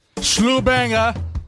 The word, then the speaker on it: Slubanger: Slubanger! Of the week. Did I say that right? – Yeah. – Okay, just making sure. It's your word. You made up this word, so I don't want to mess up your thing, okay? – No, you totally said it right.